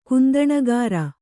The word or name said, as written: ಕುಂದಣಗಾರ